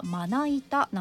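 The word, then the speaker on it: 生板: まな板ショーということですが、漢字では生の板と書くんですが、読み方はまな板なんですね。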